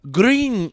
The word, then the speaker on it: Grenier: Just like how it's spelled, it's phonetic. – Yes. It's Grenier.